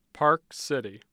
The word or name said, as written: park city